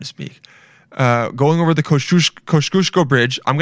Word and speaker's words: Kosciuszko: I asked this Polish guy today, how to pronounce Kosciuszko. It's a street in New York, and it's I think it's Kosciuszko. Yeah, Kosciuszko.